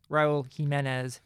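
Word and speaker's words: Jiménez: Raul Jimenez, which is how you pronounce his last name. It keeps getting butchered. I'm about to have my head explode. Raul Jimenez, 2 goals.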